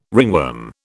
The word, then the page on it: ringworm: British English